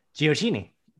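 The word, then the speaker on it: Gioacchini: I should mention quickly that in this game, Gio Reyna, Nicolas Giacchini. How do you pronounce that? – Giacchini. – Okay, Giacchini. I said it right.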